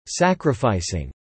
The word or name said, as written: sacrificing